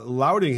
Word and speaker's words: lauding: Lauding, L-A-U-D. Lauding, I guess that's the right way to say it. – I say lauding. – Lauding, that's what I'm saying. Laud, like you're saying, like you're in a preacher in the South. – Loud.